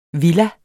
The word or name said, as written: villa